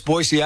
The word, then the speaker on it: Boise: Boise, you have to say it right. – Boise, Idaho, right? – People always say it with a Z, right? – That's right. It's Boise, Idaho.